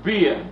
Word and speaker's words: bier: Or bier, B-I-E-R. I spell it for you in case you make a mistake. If you make a B-E-R, then it's a beer. Pronounce the same way, spelling is different.